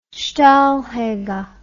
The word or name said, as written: steinhager